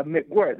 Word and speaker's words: Mcquirt: Is it McGirt? Is that how you say it? – Uh, Mcquirt. That's really close.